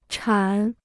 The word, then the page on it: 产: chǎn